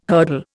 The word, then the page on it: hurdle: Indian